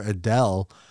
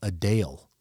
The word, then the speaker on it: Adele: Like last night we were in bed and talked for about 10 minutes about how Jenny discovered that the singer Adele, the way to pronounce her name properly is Adele.